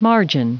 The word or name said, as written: margin